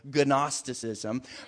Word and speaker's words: Gnosticism: But he's writing to a group of people that are getting caught up in a false teaching known as Gnosticism. Now, there's not going to be a test. You don't need to remember that word. It's spelled with a G in case you wanted to know. Gnosticism.